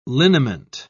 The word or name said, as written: liniment